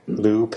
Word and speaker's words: Lupe: But they do let you know on Wikipedia that Lupe is pronounced Loopay. – Lupe.